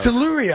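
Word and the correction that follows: Topuria: I can never say it right. – Teporia against Gaethje.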